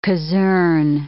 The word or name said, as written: caserne